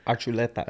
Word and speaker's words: Archuleta: Do you pronounce it Archuleta or Archuleta? – It depends who I'm talking to. – There you go. With me, how would you say it? – Archuleta.